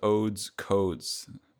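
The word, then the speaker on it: Odes/Codes: The record name is Odes, Codes. The slash is silent.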